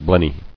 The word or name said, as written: Blennies